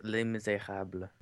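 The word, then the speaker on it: Les Misérables: Do you know how to say it properly, actually? Les Mis? It's Les Miserables.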